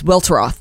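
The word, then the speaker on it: Welteroth: I think it's Weltroth. – It's Weltroth. Yeah, that is how you pronounce it. Weltroth. She says it in the book.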